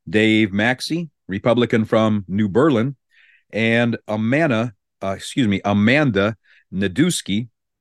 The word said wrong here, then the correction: Nedweski: I pronounced that wrong. It's Nedweski, who is a Republican from Pleasant Prairie.